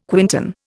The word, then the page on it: quintan: Indian